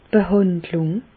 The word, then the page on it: d' Behàndlùng: Bas-Rhin; Reichshoffen